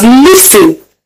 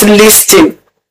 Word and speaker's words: listen: So on today's episode, we will be learning the pronunciation of the word spelled as N-I-S-T-E-N. N-I-S-T-E-N. And it's pronounced listen. Listen. Listen. And not listing.